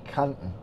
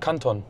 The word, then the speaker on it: Canton: Nee, ich kann es einfach nicht anders aussprechen. Canton, sagst du, ne? – Nee, ich sag Canton. – Canton. Okay, das Canton Soundsystem, ich sag Canton, was falsch ist, aber das kann.